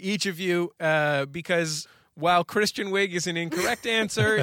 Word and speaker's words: Kristen: It's Kristen, not Christian.